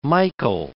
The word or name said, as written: Michael